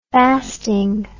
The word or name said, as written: fasting